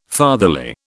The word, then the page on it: fatherly: British English